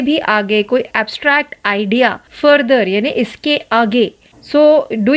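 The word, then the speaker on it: further: दूसरा जो शब्द है वो है फर्दर फर्दर F U R T H E R फर्दर इसका प्रोनाउंसिएशन भी अलग है और स्पेलिंग भी फर्दर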